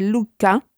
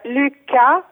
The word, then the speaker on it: Lycka: dal titolo vediamo se lo pronuncio correttamente, Lucca, Lucca, pubblicato da Opli Editore. Buongiorno. Come Buongiorno a voi. – si pronuncia il titolo del tuo libro, innanzitutto? – Allora, il titolo è Lycka, che è una parola svedese, e il titolo è Lycka, l'arte nordica della felicità.